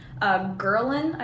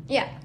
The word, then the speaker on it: ghrelin: like you typically crave more, and that effect is also exacerbated by another hormone called ghrelin, I think I'm pronouncing it correctly, ghrelin.